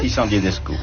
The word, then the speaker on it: Dissandier d'Escou: Et sur la place Dissandier d'Escou, je suppose que l'on prononce comme ça, je ne suis pas très sûre. – Dissandier d'Escou, oui.